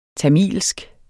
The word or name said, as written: tamilsk